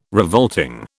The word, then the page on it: revolting: British English